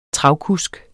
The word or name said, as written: travkusk